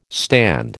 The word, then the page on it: stand: American English